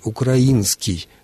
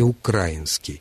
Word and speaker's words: украинский: И, кстати, в прилагательном литературный русский язык требует ударения на том же слоге «украинский», а не «украинский».